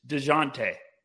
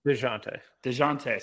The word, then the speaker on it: Dejounte: Also, it's DeJounte. – DeJounte. – Someone called me out early in the year for pronouncing it wrong, and they've had real estate in my head ever since.